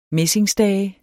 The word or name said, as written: messingstage